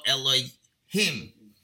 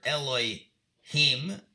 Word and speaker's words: Elohim: The word Elohim, if you're referring to judges and angels, it's pronounced Elohim. Referring to God, you have to say Elohim, because you don't want to say God's name.